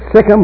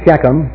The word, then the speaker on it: Shechem: Now the word Sichem or Shechem, it's pronounced both ways, means shoulder.